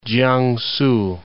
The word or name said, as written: Kiangsu